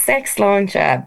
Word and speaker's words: SX Launcher: Is it Sex Launcher or SX Launcher? How do we pronounce that? It's spelled SX, but I just didn't know. Was that lingo I wasn't aware of? I don't know. – No. It's Sex Launcher, but I just spell it SX because I knew I wouldn't be able to keep social media accounts.